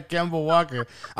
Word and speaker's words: Kemba Walker: Yeah, Kemba Walker.